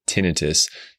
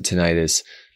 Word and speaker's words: tinnitus: Today's video will focus on the top five sound therapy apps for tinnitus. Now, some pronounce it tinnitus. As an audiologist who trained at a major hospital in San Francisco, I learned that most doctors pronounce the word tinnitus.